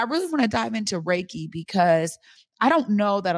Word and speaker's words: Reiki: So Reiki, the last syllable, that ki, that really is what most people are familiar with because we know it as chi, the chi moving through your body.